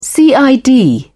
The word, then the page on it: cid: British English